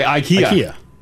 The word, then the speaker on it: IKEA: IKEA.